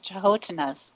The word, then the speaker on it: Canotina: I know that the Dakota Indians in South Dakota talk about the Chahotinas, which are, it's spelled Canotina, but it's pronounced Chahotina.